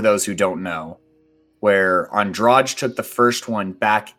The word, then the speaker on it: Andrade: So up next we have Andrade, I believe it's pronounced. – Yeah.